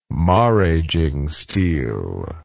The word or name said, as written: maraging steel